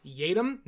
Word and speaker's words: Yiadom: It is not Yiadom, as his name looks like it's spelt out Y-A-Y-I-A-D-O-M. It's Yadom, not Yadom.